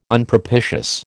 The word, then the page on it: Unpropitious: American English